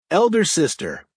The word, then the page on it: elder sister: American English